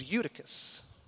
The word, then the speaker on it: Eutychus: This man's name, which Will pronounced perfectly, well done, is Eutychus.